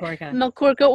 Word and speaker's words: Melkorka: His first novel, Melkorka? Did I say that right? – That is correct. – Okay. Melkorka was released in 2015, and he has been writing fantasy fiction like clockwork ever since.